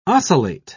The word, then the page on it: ocellate: British English